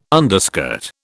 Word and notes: underskirt: British English

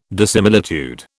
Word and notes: dissimilitude: British English

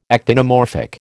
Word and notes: actinomorphic: American English

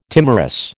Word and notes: timorous: American English

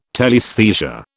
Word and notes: telaesthesia: British English

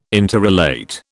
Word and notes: interrelate: British English

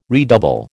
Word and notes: redouble: American English

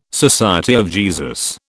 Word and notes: SJ: British English

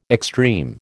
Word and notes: extreme: American English

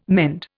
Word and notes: mend: Indian

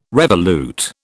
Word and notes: revolute: British English

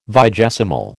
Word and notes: vigesimal: American English

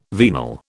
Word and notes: venal: British English

